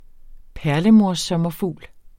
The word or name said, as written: PERLEMORSSOMMERFUGLE